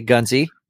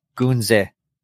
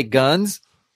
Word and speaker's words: Gunze: And I think the correct pronunciation is Gunze. That's the actually way to pronounce it correctly is Gunze. And I'm going to say Gunze because that's what I always have said. So you can, that's just how it is. Some people say guns. That's how they want to pronounce Gunze.